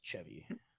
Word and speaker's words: Chevy: You don't say it like the car. It's Chevy.